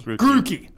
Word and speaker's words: Grookey: Grookey. – Grookey.